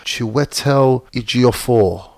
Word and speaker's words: Chiwetel Ejiofor: Chitwell Eofor. – Did you say his name? – Chitwell. – It's a very peculiar name. – It's a hard name to probably not a hard name, but there's a lot of vowels in the name. – No, it is a hard name, man. – How do you say that? – I'll speak for myself again. Like Chiwetel Ejiofor. Chiwetel. Yeah, he's a bad man actor.